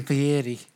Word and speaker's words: Fieri: I love the way you say Fieri. – Fieri. – Because right. I was saying Fieri. – That's how they say it.